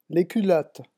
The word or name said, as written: les culottes